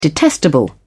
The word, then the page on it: detestable: British English